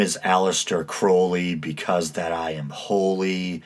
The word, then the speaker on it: Crowley: So Crowley, a lot of people pronounce it Crawley, but I believe Crowley is the proper pronunciation that he himself used.